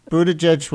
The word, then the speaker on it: Buttigieg: So I'd say you pronounced Buttigieg right and Stefanik.